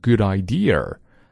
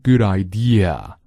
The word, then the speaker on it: idea: 比如有一个单词叫 idea， good idea，好主意，这个我们很多同学会读成 good idea。 No, that is wrong. Say good idea，不要卷舌哦，一定记住有字母 R 的时候再卷舌，比如 word。